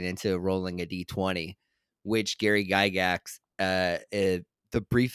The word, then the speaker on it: Gygax: Gary Gygax.